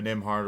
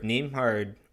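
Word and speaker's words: Nembhard: I mean, I just wonder if Nimhard, well, I mean, I guess with Ben, yeah, with Nimhard, Nimhard, is it Nimhard or Nimhard? Anyway. – It's a silent B. It's a silent B.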